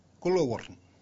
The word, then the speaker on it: Kuliwong: Your next listening section on your journey today will be at Kuliwong. Here we are at Kuliwong. Now my pronunciation for this is Gulawurrn, Gulawurrn, and what it means is that a koala threw himself down here.